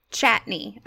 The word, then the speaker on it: Chatigny: So everyone out there listening in today, we have Kate Chatigny here with us. Did I say your last name right? – Close, it's Chatney. – Chatney. Okay, so the G is like silent.